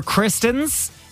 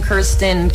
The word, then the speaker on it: Kirsten: I'm like, I'll answer Kristen, Kirsten, Kirsten, which is how you say my name, but I don't blame people.